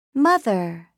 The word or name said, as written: mother